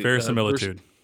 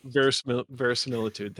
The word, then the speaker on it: verisimilitude: Verisimilitude. – Verisimilitude.